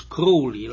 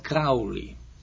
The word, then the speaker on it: Crowley: In April 1904, Rose Crowley began to, incidentally, the name is Crowley, like a crow that flies in the air, not Crowley. That's how he pronounced it himself, so we might as well give him that honor.